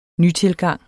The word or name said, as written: nytilgang